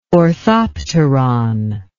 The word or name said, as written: orthopteron